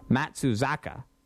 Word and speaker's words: Matsuzaka: And what's happening is the Red Sox now have just over 24 hours to sign Matsuoka. I don't know if I have the pronunciation right, Matsuzaka, to a contract.